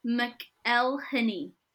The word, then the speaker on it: McElhenney: How do you say his name? It's Rob McElhenney. Like, McElhenney.